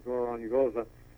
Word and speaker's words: Gorongosa: Yeah, you got the pronunciation right, Gorongosa.